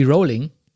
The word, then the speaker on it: Rowling: Rowling ausspricht. Aha. Sagt man Rowling oder Rowling? Sie selbst sagt, es klingt wie Rowling.